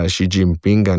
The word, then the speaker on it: Xi Jinpinga: Tako da ne morem jaz zdaj z tega Xi Jinpinga neki mehčani š delati, ker mi bo to oteževalo samo izgovorjavo.